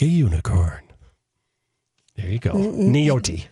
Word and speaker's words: Nyati: Nyoti is how you pronounce it. N-Y-A-T-I pronounced Nyoti.